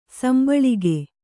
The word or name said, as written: ಸಂಭಾಳಿಕೆ